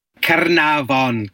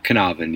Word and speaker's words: Caernarfon: Caernarfon Castle is, he put some effort into the Welsh pronunciation, but Caernarfon, yeah.